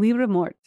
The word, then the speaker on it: Liber Morte: You do the French pronunciation, Liber Morte.